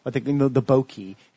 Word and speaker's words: bokeh: Is that called bokeh? – Bokeh, yeah. – How do you spell that? – B-O-K-E-H. It's a Japanese term.